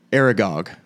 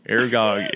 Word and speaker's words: Aragog: Aragog is Is that how you pronounce it? Aragog? – I think that's right.